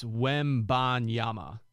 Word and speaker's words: Wembanyama: Did I get his name right? Victor Wembanyama? – I keep messing it up, too. It's Wembanyama.